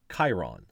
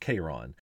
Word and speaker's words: Chiron: But there's another centaur, Chiron, sometimes pronounced Chiron, who embodied everything that the violent centaurs did not.